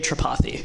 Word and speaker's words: Tripathi: AJ, how do you say your last name? It's Ajay Tripathi. – Ajay. Okay, Ajay Tripathi. Yeah. All right. I just have to put the emphasis on the other syllable.